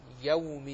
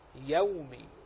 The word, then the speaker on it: يوم: فمن الحرص على صفة اللين أن تنطق بالفتحة قبل الواو فتحة كاملة، يومي، لأن الواو دائما تستدعي الضمة. مررنا ونحن نتكلم عن الحروف المادية الثلاثة أن الواو يجانسها الضم، فهي دائما تميل إلى مجانستها إلى الضم، وتريد أن تأخذ الفتحة نحو الضم. فلذلك تجد بعض الناس ينطق هكذا مالك يو يوم الدين يو، فينطق يو، وهذا خطأ، وأصلا حركة أو غير موجودة في